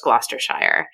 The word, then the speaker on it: Gloucestershire: Daylesford Farm is in Gloucestershire, and Gloucester, of course, is spelled G L O U C E T E. So, I mean, my first instinct is to say Locator- Gloucestershire, but it, that's very wrong. It's just Gloucestershire.